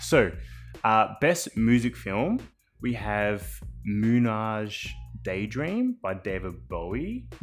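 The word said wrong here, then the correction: Moonage Daydream: Is that how you pronounce it? – Oh, Moon Age Daydream. – Moon Age, my bad. – That's David, you know, wake up in a Moon Age Daydream.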